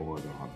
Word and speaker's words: Oregon: Then we had Michigan State at three, and then Oregon, that's how they say it, Oregon, at four.